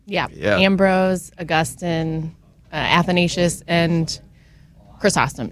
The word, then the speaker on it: Chrysostom: Michelle and Desiree made a one-time pledge in honor of the Chrysostom House. So let's go. – Chrysostom. – Oh, my goodness. Well, so how do you pronounce that? I just butchered that. What was that? – Chrysostom. – Chrysostom.